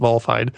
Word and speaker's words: Volfied: Make sure that we understand that it's pronounced Volfied.